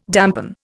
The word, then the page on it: dampen: Indian